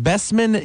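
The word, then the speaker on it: Bečmen: Her birth date is March 2nd of 1938, and she's been born overseas, or she was, in Besmin, Yugoslavia, I believe. Is that correct, Hermina? – That's correct.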